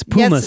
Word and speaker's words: pumice: That's pumice. Yes, Stephanie is correct. It's spelled P-U-M-I-C-E. – But pumice, not puma, not the cat.